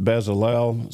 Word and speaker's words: Bezalel: I pronounce it Bezalel. Some say Bezalel.